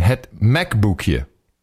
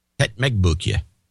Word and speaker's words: Het McBoekje: Okay, Het so Mech, let me give you the pronunciation. Het McBoekje. – Het McBoekje.